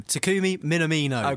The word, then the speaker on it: Takumi Minamino: He's from Red Bull Salzburg, Japan International, Takumi Minamino. Is that anywhere close to what it should be? – I'll help you out. Takumi Minamino.